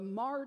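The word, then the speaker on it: Hmar: There was a particular tribe called the Mar tribe, which, believe it or not, is spelled with an H, H-M-A-R, the Mar tribe.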